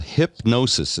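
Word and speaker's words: Hipgnosis: Mercurios is called Hypnosis. It's spelled HIP as in hip replacement. Mm. Gnosis, GNOSIS.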